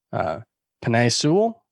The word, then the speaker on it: Penei Sewell: Cincinnati's offensive line is likely to be better, especially if, you know, I think they should do the obvious and take Penei Sewell.